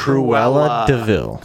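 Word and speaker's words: Cruella de Vil: Cruella DeVille. – Koala DeVille. – You like still got it wrong.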